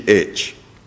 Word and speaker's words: Conemaugh: A massive rainstorm filled Lake Conemaugh, and that is Conemaugh, lest I pronounce it incorrectly,